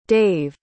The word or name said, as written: dave